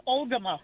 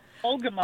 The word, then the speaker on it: Algoma: It's Algoma. Algoma. – Oh, I'm sorry. Yep, I mispronounced that.